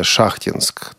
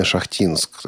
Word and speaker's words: Шахтинск: Шахтинск, такой вариант, который словари рекомендуют, угу. но таки есть местный вариант, ростовский, южный вариант, действительно Шахтинск, замечательный город, у которого несколько вариантов произношения.